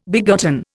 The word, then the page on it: begotten: Indian